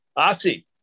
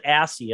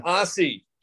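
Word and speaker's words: Aase: Aussie. – I almost said Assie, and I didn't think that would be very nice. – Don Aussie.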